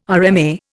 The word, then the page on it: RMA: Indian